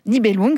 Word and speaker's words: Nibelung: Nibelung, oui.